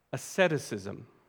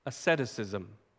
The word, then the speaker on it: asceticism: The single best word for it is asceticism. Asceticism. It's maybe a word you'd like to write down. It's spelled A-S-C-E-T-I-C-I-S-M. A-S-C-E-T-I-C-I-S-M.